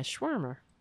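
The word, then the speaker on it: Schwerner: Goodman, Shermer, am I pronouncing it right? And Cheney? Is it Shermer? Cheney? Sure, yeah, Schwermer. I said it right.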